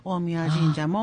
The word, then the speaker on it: 大神神社: それから大神神社って大きい神様って書いて大神神社って読むんですけども、三輪山をご神体とされた大神神社も、とてもエネルギーの高い、初詣のね、参拝場となると思いますね。